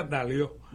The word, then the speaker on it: Daliot: Mi nombre es Daliot, es francés. – Sí. – Se pronuncia Daliot.